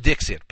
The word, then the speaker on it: Dikshit: And his name really is Dixit. Pronounced Dixit, but D-I-K-S-H-I-T. Can you imagine that name?